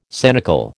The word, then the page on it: sanicle: American English